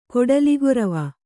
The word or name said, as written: ಕೊಡಲಿಗೊರವ